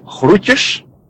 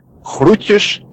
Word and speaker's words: groetjes: and the correct pronunciation of that is groetjes. I'll repeat, groetjes, and it just means greetings.